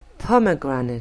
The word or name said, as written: pomegranate